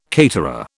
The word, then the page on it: caterer: British English